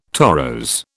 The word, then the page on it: torose: British English